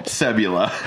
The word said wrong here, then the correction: Sebulba: Sebulba. – It's Sebula. – It's Sebulba. – Oh, okay. – They say it in the movie multiple times.